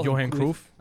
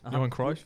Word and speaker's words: Johan Cruyff: They call Johan Cruyff. Johan Cruyff.